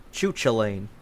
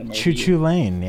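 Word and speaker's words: Cú Chulainn: Uh, got got the shield of uh, because people can't pronounce that name right. Chuchulain. – Choo Choo Lane, yeah.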